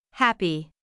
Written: happy

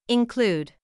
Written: include

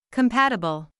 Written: compatible

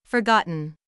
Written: forgotten

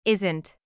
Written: isn't

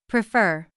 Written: prefer

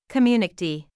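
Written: community

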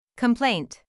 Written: complaint

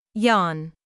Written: yawn

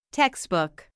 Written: textbook

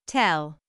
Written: tell

